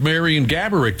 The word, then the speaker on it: Gorbiak: And the sales department approached me and said, hey, you know, this Saturday afternoon, would you like to make an appearance alongside Marion Gorbiak, of course, is the real pronunciation, but Josh's uncle liked to make up different pronunciations. Would you like to make an appearance with Marion Gorbiak this weekend at a bar or something like that?